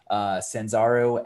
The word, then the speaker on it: Sanzaru: So that's, and once again, I might also be pronouncing this incorrectly, Senzaru, S-A-N-Z-A-R-U. – I think that's right, yeah.